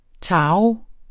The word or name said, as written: taro